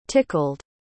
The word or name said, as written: tickled